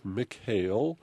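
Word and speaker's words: Michale: It's M-I-C-H-A-L-E. It looks like Michael Callahan, but it's pronounced Michael.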